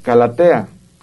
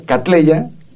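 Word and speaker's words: Cattleya: calatea, y yo, sí, porque se pronuncia mal, catleya. Ya me he encontrado varias catleyas niñas, es un apellido inglés de catle, que le dedicaron el género.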